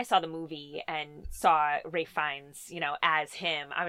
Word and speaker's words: Ralph Fiennes: that's how you say his name? – Yeah, Ralph. – It's not Ralph Phineas? – No, it's Ralph. Fuck. Ralph Fiennes.